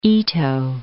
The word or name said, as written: Ito